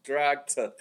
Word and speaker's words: Dragt: Dragt.